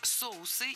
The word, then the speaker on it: соусы: В этом высказывании прозвучало сразу две ошибки – соуса и блюда. Оба слова имеют неподвижное ударение на корне. Соответственно, должны быть соусы и блюда. Ударение ставим на первый слог в обоих словах, во всех формах.